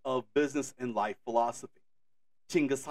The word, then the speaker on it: Chinggis: I have friends who live in Mongolia, so I'm going to use a closer to Mongolian pronunciation of his name, of Chinggis Khan.